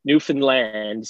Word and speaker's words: Newfoundland: So you go, it's Newfoundland. So you stress the land and you go through Newfoundland really quick.